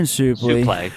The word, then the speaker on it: Suplee: Not to mention it's got Ethan Suplee, I think is how you pronounce his last Suplee. – Name. – Yeah, Ethan Suplee. – Suplee. – Suplee. – It's not souffle. It's not a fucking French cuisine.